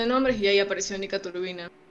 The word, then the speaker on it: Turbina: como una va en realidad se, pronuncia distinto en, ruso. Es Nika Turbina. Ah. – Y nosotros le cambiamos el acento para que suene turbina.